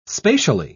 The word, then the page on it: spatially: British English